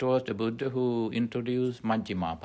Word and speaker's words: Majjhima: The middle path, Majjhima Patipada, M-A-J-J-H-I-M-A, Majjhima, long A, Majjhima, then Patipada, P-A-T-I, diacritical mark, dot underneath T, pa, T, I, P-A-D-A, Patipada, Majjhima, Patipada, the middle path.